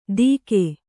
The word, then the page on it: ತೀಕೆ: Kannada